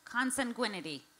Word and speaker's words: consanguinity: How do I pronounce that word, guys? – Consanguinity? – Say it one more time. Consanguinity. – Yeah. – Okay, we have consensus.